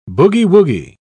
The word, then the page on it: boogie-woogie: British English